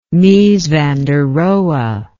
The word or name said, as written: mies van der rohe